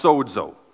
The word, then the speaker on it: sozo: It's pronounced sozo.